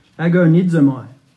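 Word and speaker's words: agonizomai (ἀγωνίζομαι): The Greek word is agonizomai. Agonizomai. So our word agony or agonize comes from this Greek word.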